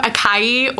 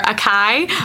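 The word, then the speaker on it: açaí: Yeah, so a lot of people, it's spelled A-C-A-I. So we get a lot of acai or acai, yeah. But it's actually acai.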